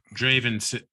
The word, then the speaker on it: Draven: Draven rhymes with raven, and raven is like a crow.